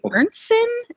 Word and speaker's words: Ernstsson: Ernstsson? Is that? – Sure. – How do you say it? – my dad's name is Ernst, so I'm Ernst's son. So Ernstsson.